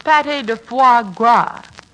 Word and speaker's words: pâté de foie gras: Crepe Suzette and goose livers, patty de faux pas. – Archie, that's pronounced pâté de foie gras.